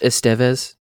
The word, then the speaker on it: Estevez: It's pronounced Estevez, actually, in Spain.